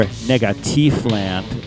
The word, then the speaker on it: Negativland: And we started off with Negative Land, or Negativ Land, if you want to be technical, because they're named after the German group. And that's how you say it, if you're pretentious.